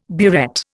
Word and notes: burette: Indian

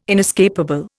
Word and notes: inescapable: Indian

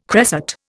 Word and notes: cresset: Indian

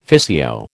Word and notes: physio: American English

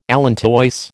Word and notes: allantois: American English

